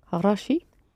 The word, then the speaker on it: хороший: Knowing that the ending you have to add is unstressed, e, and the accent will fall in the middle part of the adjective. What would be good? – Хороший. – It's о that is accented.